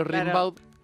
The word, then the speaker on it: Rimbaud: Claro, como se escribe, se pronuncia Gimbaud, pero Rimbaud.